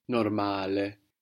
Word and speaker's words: normale: So to get normal in Italian, we will say normale. – Normale. – Good. So we have the accent on the second last syllable, no? – On the a normale.